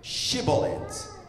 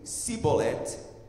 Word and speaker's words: Shibolet: Se respondessem que não, diziam então diga Shibolet. Se ele dissesse Sibolet, sem conseguir pronunciar corretamente a palavra, prendiam-no e matavam-no nos vales do Jordão.